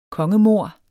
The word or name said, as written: kongemord